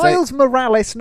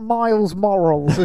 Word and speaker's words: Miles Morales: Miles Morales, not Miles Morals, as you said when we were talking about this off the podcast.